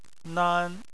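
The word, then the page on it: nan1: tone 1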